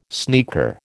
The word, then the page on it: sneaker: American English